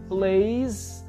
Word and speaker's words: plays: Eu vou falar, he plays the piano. Eu coloquei um S, só que o S, nesse caso, vai ser pronunciado como Z.